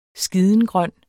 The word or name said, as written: skidengrøn